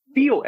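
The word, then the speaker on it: FEAL: By the way, for those listening, it's spelled FE:AL, and it's pronounced feel.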